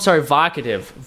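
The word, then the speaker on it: Vocativ: Vokovit, is that how you pronounce it? Vokative. Vokative. Wow. – Oh, it's like provocative.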